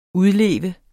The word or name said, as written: udleve